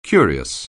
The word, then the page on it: curious: anglais